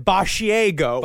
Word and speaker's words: Basciago: He goes, it's spelled as Basciago. It's spelled like a mixture of barf and Asiago, the cheese. But it is, he says it Basciago.